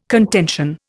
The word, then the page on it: contention: Indian